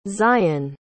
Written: tziyon

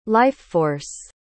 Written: life-force

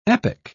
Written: epoch

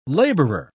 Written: laborer

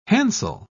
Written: hansel

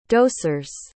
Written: dosers

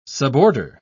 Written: suborder

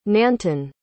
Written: nantan

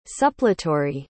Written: suppletory

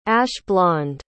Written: ash-blond

